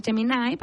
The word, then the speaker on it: Gemini: Casi todos los que tienen Android tienen Géminis ya incorporado, se escribe así, Géminis, se pronuncia Gemini, pero para que ustedes lo puedan buscar en español, Géminis con G.